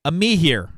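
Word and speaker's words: Amihere: I always butcher her name. Amihir. I got it right.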